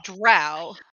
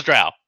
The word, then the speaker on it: drow: No, they're always murdered because drow. – Because drow, right? – Or as some people like to pronounce it, dro, and those people are wrong.